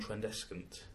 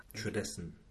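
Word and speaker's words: Tradescant: But John Trendescant went above and beyond... – I don't think you have to pronounce the C. – Do you not? – Trendescant. Yeah, okay. That's what Will Self said.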